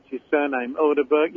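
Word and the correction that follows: Ødegaard: you know, the teenager that Real Madrid signed, the Norwegian boy. – Martin Odegaard, he slandered, didn't he?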